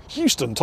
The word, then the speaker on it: Houston: So I said, Tall 50 Hey, it's Houston. Houston. Houston. That's how you say it. Say it back to me. Houston, Tall 50.